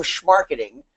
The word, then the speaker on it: schmarketing: Uh, Alex, you learned today in 2007, I invented the term schmarketing with my buddy Mike Volpe, right? But you got to say it right. You got to use the back of your throat.